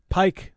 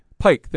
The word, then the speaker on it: Piëch: That's P-E-E-C-H. P-I-E-C-H. So how would you pronounce that? – Pike. – Pike, thank you. I think of P-I-K-E.